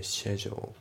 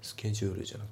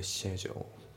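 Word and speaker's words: schedule: で発音ですけど、タイムアウトのところがリンキングでタイムアウトだね、タイムアウトじゃなくてタイムアウト、うん、であとスケジュールのところなんですけど、これイギリス英語の場合ってシェジュールっていう風に発音されるんですよね。スケジュールじゃなくてシェジュールですね。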